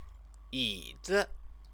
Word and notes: yizi: Chinese

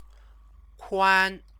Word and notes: kuan: Chinese

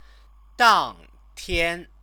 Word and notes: dangtian: chinese

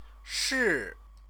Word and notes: shi: Chinese

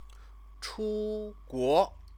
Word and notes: chuguo: chinese

